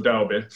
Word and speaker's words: Daube: I think it's sounded E, so Daube.